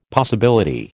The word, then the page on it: possibility: American English